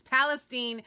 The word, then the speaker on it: Palestine: We've been saying it wrong. It's very hard because there's Palestine and now we've got Palestine, but I've been corrected. Jeff tells me he was also corrected. It's Palestine.